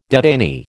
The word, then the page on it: detainee: American English